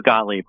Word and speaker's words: Gottlieb: It is Gottlieb.